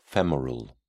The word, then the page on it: femoral: British English